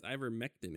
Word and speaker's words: ivermectin: And what, the Invector? – It's ivermectin, isn't it? – Ivermectin, yeah.